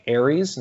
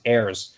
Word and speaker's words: Ayers: But even then, like, I think the first couple times I said it on air, I think I pronounced his name like Aries instead of Ayers, just because I didn't know. – Oh, you had no idea. And you yeah. Wait, and so that was on me to not double check that initially. – Okay, really, yeah. – But thankfully early on, it was actually a member of the Leafs PR staff said, hey, just so you know, it's pronounced Ayers. And so I relayed that to the team, and then we had it from that point forward.